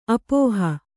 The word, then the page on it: ಅಪೋಹ: Kannada